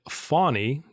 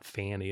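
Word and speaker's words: Fani: I saw today there's an Atlanta Journal-Constitution article that Fawny, which is the correct pronunciation, they note in that article, like a fawn, like a deer, but not Fanny, it's Fawny.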